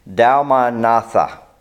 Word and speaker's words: Dalmanutha: Dalmanutha. Let me say that right, because I have it written down here so I would get it right. Dalmanutha, that's how it is.